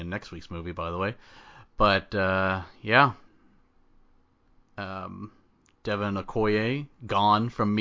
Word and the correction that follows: Aoki: You just butchered that enunciation. It's Aoki.